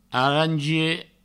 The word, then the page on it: arangier: arangie